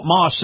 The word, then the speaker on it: Maus: And before you buy elsewhere, check out Moss. That's spelled M-A-U-S.